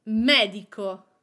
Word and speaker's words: medico: The same for medico. Medico means doctor. The accent is on the first syllable, me, medico. Tavolo.